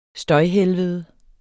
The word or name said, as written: støjhelvede